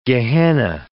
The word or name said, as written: Gehenna